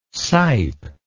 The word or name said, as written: sipe